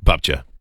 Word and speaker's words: babcia: And grandma's Babcia? – Babcia.